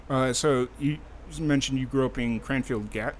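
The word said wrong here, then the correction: Cranfills Gap: Cranfield's Gap.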